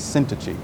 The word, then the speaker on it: Syntyche: So I went to the internet and I got the pronunciation. The first one, first lady's name is Euodia, and the second is Syntyche.